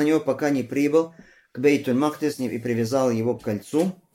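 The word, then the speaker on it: бейт-аль-Макдис (بيت المقدس): Первое это с фатхой буквы мим, то есть аль-Макдис. Хорошо? Бейт аль-Макдис. Это первый вариант. Второй вариант буква мим с ламмой и каф и даль с шаддой. Мукаддас.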